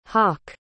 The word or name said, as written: hawke